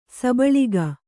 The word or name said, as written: ಸಬಳಿಗ